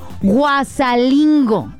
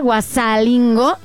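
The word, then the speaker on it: Huazalingo: Me dice, y pronuncia bien el nombre que te di. Dice, es Guasalingo. A ver, espérenme tantito. ¿Cómo estaba? Guasalingo. ¿Y qué dije? Ténganme paciencia con los nombres. Dice, este lugar, Guasalingo, Guasalingo, ¿sí? Así se pronuncia.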